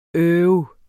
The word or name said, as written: øv